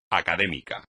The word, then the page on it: académico: espagnol